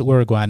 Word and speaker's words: Uruguay: I know it's Uruguay now. I guess I was just mispronouncing it.